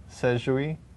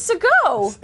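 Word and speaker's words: Segoe: Is that how you pronounce Sego? Or is it Sejui? – What? – I don't know. – It's Sego.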